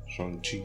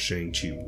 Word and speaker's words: Shang-Chi: Shang-Chi, I think is how they pronounce it, actually.